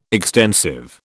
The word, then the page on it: extensive: British English